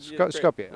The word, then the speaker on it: Skopje: So after Skopje in Macedonia, am I saying it right? – Yeah, you're good. – Skopje.